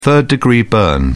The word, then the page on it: third degree burn: British English